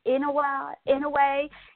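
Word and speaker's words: Inoue: Inouye, how do you say his name? – Inouye.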